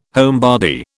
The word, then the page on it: homebody: British English